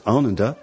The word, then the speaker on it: Ananda: When I got involved in the Dharma, I was a tender lad of 17 in Brighton, frequenting the local hippie shop, which everybody called Ananda, which I know now is to be pronounced as Ananda.